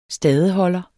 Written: stadeholder

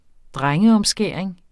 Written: drengeomskæring

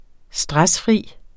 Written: stressfri

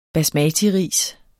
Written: basmatiris

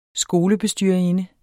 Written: skolebestyrerinde

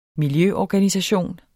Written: miljøorganisation

